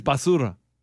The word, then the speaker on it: basura: It is basura. – Basuda. – It's trash can. Trash. – Trash, yeah. – Did I not say it right? – Say it again. – Basura. – Basura. Yeah, pretty good.